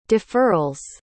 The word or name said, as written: deferrals